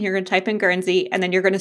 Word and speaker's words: Guernsey: So first question for you is, you live in Guernsey.